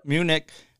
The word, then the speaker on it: Munich: Is that how you say it? – Yep. – Munich.